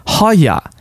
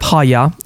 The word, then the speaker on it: haya: One of them is haya. Now, these are, and again, I'm pronouncing it so you can look it up, haya, H-A-Y-A, haya.